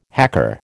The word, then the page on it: hacker: American English